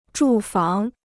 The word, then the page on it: 住房: zhù fáng